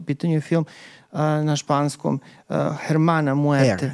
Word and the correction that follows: Hermana: Her, Hermana, H se ne izgovara na početku. Hermana Muerte ili Sister Death?